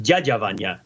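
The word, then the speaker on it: Dyadya Vanya (Дядя Ваня): And, and also that it's pronounced Dyadya Vanya yeah.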